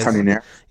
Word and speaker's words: Cannonier: Cannonier.